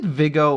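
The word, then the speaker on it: Viggo: Uh, is it Vigo or Vigo? – You always oh.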